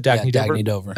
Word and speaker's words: Dagne Dover: Dagne Dover.